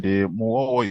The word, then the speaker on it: Mughogho: My name is actually Joseph Mughogho, and Mughogho is actually pronounced as M-U-W-O-W-O.